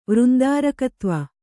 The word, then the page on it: ವೃಂದಾರಕತ್ವ: kannada